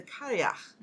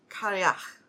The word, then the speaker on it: Cailleach: There's a couple of different ways to pronounce it, according to what I heard on, you know, like on YouTube. Mm-hmm. How do you pronounce? Blah, blah. Mm-hmm. And the most common that I heard was Kariach. – Okay. Spell it. – It is spelled C-A-I-L-L-E-A-C-H.